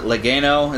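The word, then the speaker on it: Legeno: But Dave, I think his name, you pronounce it Legano.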